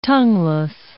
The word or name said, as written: tongueless